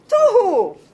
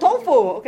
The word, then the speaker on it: tohu: This is to, to, who, tohu, not tofu, okay? Tohu. See, you're reading now. Just tohu vavohu.